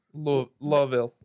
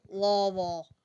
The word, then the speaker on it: Louisville: You got to say it like the people from Louisville do. – Louisville. – Louisville.